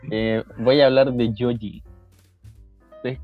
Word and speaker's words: Joji: ¿Cómo se escribe? Hoji. Se escribe Joji porque tiene ascendencia japonesa y australiana.